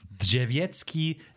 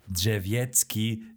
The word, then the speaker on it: Drzewiecki: Drzewiecki, Drzewiecki, Drzewiecki.